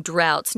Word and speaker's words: drought: people suffer from droughts. Notice it's a weird pronunciation drought. But that's how we say it.